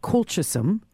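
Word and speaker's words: Colchicum: It's called Colchisum. – Can you spell that? – C-O-L. – C-O-L? – C-H. – C-A? – C-H. So colchisum, C-U-M. – C-U-M, all right.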